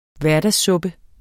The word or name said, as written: hverdagssuppe